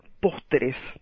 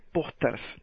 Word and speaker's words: pósteres: En español siempre lo pronuncio pósters, como en inglés, y le agrego una vez al final. Pero bueno, parece que la forma correcta es pósteres, así que hablemos de pósteres o póster en singular.